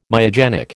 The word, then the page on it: myogenic: American English